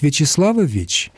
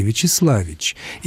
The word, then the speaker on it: Вячеславович: Вы будете произносить Вячеславович? Вы с ума сойдете, пока вы это сделаете. Вы, конечно, будете произносить так, как еще в Древней Руси произносили Вячеславич. И это будет правильно. Точно так же, когда произносит Татьяна Вячеславовна, это звучит...